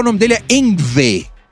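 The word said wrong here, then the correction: Yngwie: Você tem que saber. Pronunciar que, em sueco. Polir o seu sueco, falar Engvi e é Engvi J.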